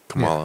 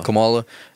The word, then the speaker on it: Kamala: Kamala. – Yeah. Kamala.